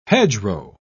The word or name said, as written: hedgerow